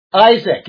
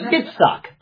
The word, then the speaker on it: Yitzhak: Isaac, yeah, it wouldn't be. It would be Yitzhak.